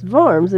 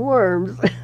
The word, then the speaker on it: Worms: Now, that city is spelled W-O-R-M-S and pronounced Worms in German, since the W, beginning W, has the sound of V. So I was amused that many in the American community said Worms.